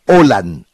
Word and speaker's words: Haaland: hablar de Haaland cada que se refería al jugador que usted y yo llamamos Alan.